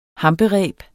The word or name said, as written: hampereb